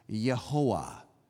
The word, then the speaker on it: Jehovah: That's just an alternate spelling of the same word, because Jehovah, we mispronounce it in English, Jehovah, because unlike almost any other language, we say J and V, J and V, Jehovah. But in most languages, you would pronounce this Yehoah.